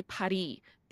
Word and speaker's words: Paris: even in France, they don't pronounce it Paris. They say Paris. They don't pronounce the S.